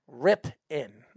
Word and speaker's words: Rypien: I just don't tell Speedy how to pronounce Rypien. Rip in. He is saying it wrong.